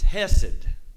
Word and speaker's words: hesed: It's hesed. You actually have to have that guttural thing, that hesed is what it really is, but I don't want to do that because you're going to think I'm trying to clear my throat.